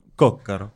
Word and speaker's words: Coccaro: Carlo Coccaro. Je le dis bien, Carlo? – Coccaro. – Coccaro.